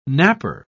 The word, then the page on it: napper: British English